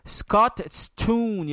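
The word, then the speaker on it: Scotstoun: C'est dur à prononcer en fait, Scottstoun, il y a comme un TST, Scottstoun Stadium.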